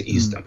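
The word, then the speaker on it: Eastham: Там находится городок Истон, который пишется как Истхэм, но произносится Истом.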